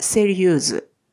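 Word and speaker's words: sérieuse: え男性系の方がセリユ女性系はセリユーズ、セリユーズです。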